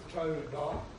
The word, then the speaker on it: towdah: And it's spelled T-O-W-D-A-H, but it's pronounced toe, as in on your foot, toe da.